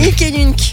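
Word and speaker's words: hic et nunc: Alors Ick, ça s'écrit H-I-C et Nunc, ça s'écrit N-U-N-C. – Voilà, et si vous n'aimez pas le théâtre, et ben, au moins vous aurez appris comment on dit ici et maintenant en latin.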